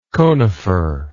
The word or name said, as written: coniferization